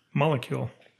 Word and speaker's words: molecule: Molecule. – Back in, you had to put the emphasis on the first part of it. Molecule.